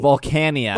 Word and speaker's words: Volcanion: I keep saying I keep saying it wrong. What I saw of the Volcaronin movie. – Volcanion. – Volcanion movie.